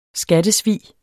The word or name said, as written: skattesvig